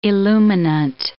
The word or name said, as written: illuminant